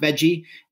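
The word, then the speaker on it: Vejii: It's spelled like V-E-J-I-I. I think it's pronounced Veggie.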